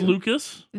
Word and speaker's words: Lukes: Has it been pronounced Lucas? – This whole time. – Why does this say Luke's then? – Because that's how his last name is spelled. – I bring it up because it says it's spelled Luke's. Pronunciation guide says Luke's.